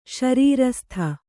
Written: ಶರೀರಸ್ಥ

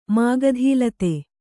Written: ಮಾಗಧೀಲತೆ

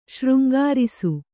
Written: ಶೃಂಗರಿಸು

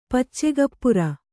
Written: ಪಚ್ಚಗಪ್ಪುರ

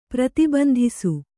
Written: ಪ್ರತಿಬಂಧಿಸು

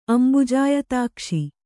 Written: ಅಂಬುಜಾಯತಾಕ್ಷ

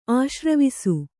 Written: ಆಶ್ರವಿಸು

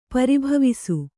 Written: ಪರಿಭವಿಸು

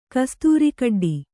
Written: ಕಸ್ತೂರಿಕಡ್ಡಿ

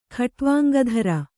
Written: ಖಟ್ವಾಂಗಧರ

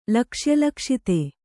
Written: ಲಕ್ಷ್ಯಲಕ್ಷಿತೆ

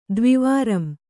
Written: ದ್ವಿವಾರಂ

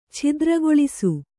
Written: ಚಿತ್ರಗೆಲಸ

